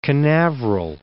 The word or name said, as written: Canaveral, Cape